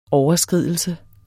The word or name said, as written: overskridelse